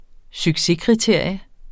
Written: succeskriterium

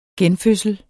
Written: genfødsel

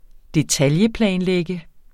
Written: detailplanlægge

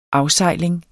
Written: AFSEJLINGEN

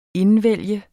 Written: INDVALGTE

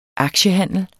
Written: aktiehandler

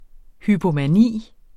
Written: hypomani

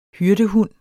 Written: HYRDEHUND

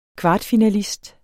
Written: kvartfinalist

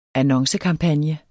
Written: annoncekampagne

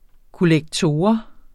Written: kollektor

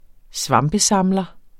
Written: svampesamler

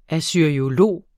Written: assyriolog

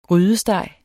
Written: grydesteg

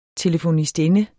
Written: telefonistinde

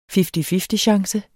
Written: fifty-fifty-chance

